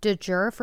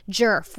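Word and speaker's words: Djerf: I said Matilda DeJerf or something on TikTok, and someone's like, it's actually Jerf without the J, or I don't know.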